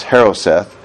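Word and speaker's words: charoset: All right, so Haroseth, and that's spelled C-H, but it's pronounced Haroseth,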